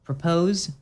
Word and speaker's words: PR0P0SE: So this band took a lot of searching because I saw it on a Rate Your Music list, and it's called Propose, except it's spelled P-R-0-P-0-S-E. – Okay. – It's spelled with zeros.